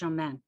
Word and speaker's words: Jamin: It's pronounced Jamin. So he said, we're going to spell it D apostrophe J-A-M-I-N.